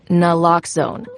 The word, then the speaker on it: naloxone: Naloxone reverses an opioid overdose, even if you mispronounce it. For the record, it's Naloxone. Like, is Naloxone addictive? Lox like Naloxone keeps humans safe, like locks keep bikes safe.